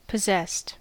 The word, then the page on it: possessed: American English